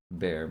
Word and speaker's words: Baer: But yeah, I wanted to involve the bear because my middle name is Bear, Matthew Bear McGlathery, and it's spelled B-A-E-R, mom's maiden name.